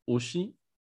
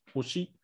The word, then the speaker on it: Hoshi: Ushi, which is the the French pronunciation of the Japanese word, which is which is hoshi, which is like star.